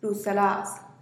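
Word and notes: rusala'az: Teotitlán del Valle Zapotec

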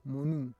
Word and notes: monu: Roon

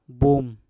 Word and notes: bum: Mpur

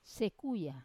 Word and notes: sekuya: Wamesa

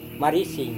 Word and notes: hanewai-marising: Serewen

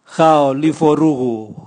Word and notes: gaolivorugu: Raga

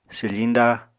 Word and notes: swfiñda: apatani

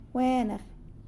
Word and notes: Gwenag: Fine.

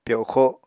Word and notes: pyoxo: Apatani

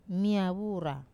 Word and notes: mia vura: Wamesa

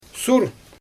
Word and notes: sur: Xyzyl

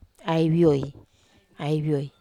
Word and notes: ai vioi: Wamesa

